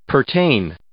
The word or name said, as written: pertain